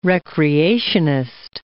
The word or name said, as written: recreationist